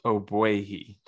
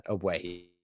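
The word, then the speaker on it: Ogbuehi: It's Ogboihe. The G is silent. – That's not too bad. Ogboihe.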